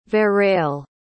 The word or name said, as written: verrell